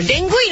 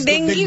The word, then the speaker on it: ডেঙ্গু: হ্যাঁ ডেঙ্গুই না অরিজিনাল উচ্চারণ হবে ডেঙ্গি